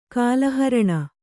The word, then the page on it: ಕಾಲಹರಣ: kāla haraṇa